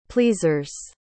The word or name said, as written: pleasers